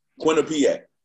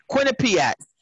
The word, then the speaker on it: Quinnipiac: Quinnipiac. – Quinnipiac.